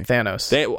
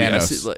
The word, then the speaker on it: Thanos: Thanos. – They say it both ways in the movie. – Thanos.